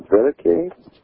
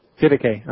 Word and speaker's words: Didache: Yeah, it's D-I-D-A-C-H-E. – C-H-E. – Yeah, C-H-E, yeah. So I said Didache. It's possible that it should be pronounced Didache. It's a Greek word.